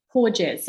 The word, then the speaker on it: Porges: Porges, so P-O-R-G-E-S.